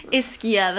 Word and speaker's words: Ischia: Ischia, that's how you say it, our boat guide said, which is an island across, like, the sea from Naples.